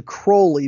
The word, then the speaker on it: Crowley: It was originally Crowley, but he changed the pronunciation to Crowley because he wanted it to sound like the word holy.